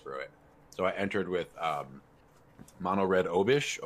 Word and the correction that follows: Obosh: Obush. How do you say mm-hmm. his name? Obosh. – Obosh. Obosh.